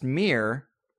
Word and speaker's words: Mihr: It's the only active, still-standing pagan temple in Armenia, and it was actually built, they believe, to venerate, I believe it's pronounced Mir, M-I-H-R,